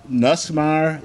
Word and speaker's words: Nussmeier: I've been hearing about Nussmeier.